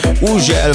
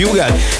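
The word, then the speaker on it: Hugel: így van, az úriember, akinek minden országon másképp ejtik a nevét, valahol Ugel, valahol Hügel, valahol Ugel, meg mindenféleképp, de nálunk úgy mondjuk, hogy Hügel, és Salto és a Jamaican Bam Bam érkezik, csavarjátok fel a hangerőt!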